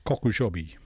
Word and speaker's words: Kokushobi: K-O-K-U-S-H-O-B-I. Kokushobi.